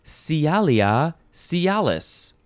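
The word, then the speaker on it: Sialia sialis: The species name of this bluebird is Cialia cialis. And that is spelled with an S, S-I-A-L-I-A, and then the next word is S-I-A-L-I-S, Cialia cialis.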